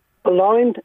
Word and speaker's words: Boulogne: Boulogne in France.